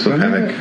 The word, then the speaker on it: Squamish: Yes, this is Squamish. – Okay. I think it's Squamish. Yes, it's Splatine, the lakes, the Shuswap Lakes area.